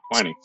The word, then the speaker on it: Twinings: I call it Twinings. No, you got it right. Twinings.